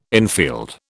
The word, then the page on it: infield: British English